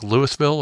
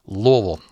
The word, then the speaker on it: Louisville: Is that like Louisville? Or it's like, no, it's Louisville. I taught many people how to say it. You just have to say it like you got a mouthful of marbles.